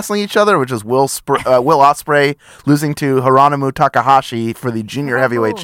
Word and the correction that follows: Hiromu: Hironomu? Is that right? – Hiromu. It's Hiromu. – Hiromu Takahashi. Yeah. See? – Man is silent.